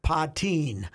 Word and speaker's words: Puttin: So the Gophers just hired a young lady for their sports information department, and her last name is spelled P-U-T-T-I-N. – No. – P-U-T-T-I-N. – Uh-oh. – So Putin. I checked. – Putintang. – She pronounces it Poteen.